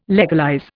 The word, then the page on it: legalize: Indian